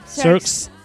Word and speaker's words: CERCS: CERCS. Se pronuncia. – CERCS. – Muy bien.